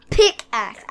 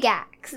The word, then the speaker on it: pickaxe: Pickaxe. I don't say it right. I say pigaxe. – So no, try it again. Pickaxe. – Pickaxe. – There you go.